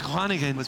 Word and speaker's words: Groningen: Nice, Groningen. – How was my pronunciation? – It was good.